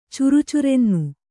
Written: ಚುರುಚುರೆನ್ನು

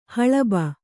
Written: ಹಲುಬು

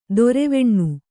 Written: ದರ್ವಾನ

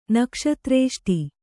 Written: ನಕ್ಷತ್ರೇಷ್ಟಿ

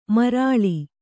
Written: ಮಾರುಳಿ

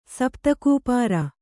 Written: ಸಪ್ತಕೂಪಾರ

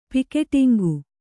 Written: ಪಿಕೆಟಿಂಗು

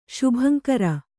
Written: ಶುಭಂಕರ

